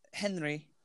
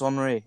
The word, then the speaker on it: Henri: Henry. – It's Henri. It's Henri Le Pigeon. – I literally couldn't remember. And it's spelled Henry, so that's why I was like, Henry. – Henri. It's French. Henri.